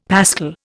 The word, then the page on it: pascal: Indian